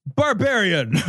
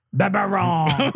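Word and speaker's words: Barbarin: Philippe Barbarian. Sorry, that's not how it's pronounced.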